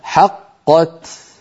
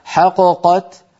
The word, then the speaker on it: حقت: لو قلقلت الأولى لنفك الإدغام، يعني لو قلنا حققت حققت فانفك الإدغام وصار كأننا نطقنا بقافين منفكتين، وهذا لا يصح أبدا، لابد أن نقول حقت.